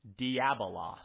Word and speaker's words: diabolos: Diabolos.